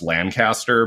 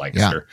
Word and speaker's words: Lancaster: Lancaster, for those of you listening who know, looks like it should be pronounced Lancaster, but it's pronounced Lancaster.